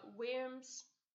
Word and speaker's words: wyrms: I think they're actually called just worms. – W-Y-R-M-S. – Yeah, I think, I've seen a TikTok going through it, and I think it's actually just pronounced worm.